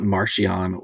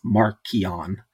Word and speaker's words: Marchion: It's not Marchion or Maraschino. It's Markion.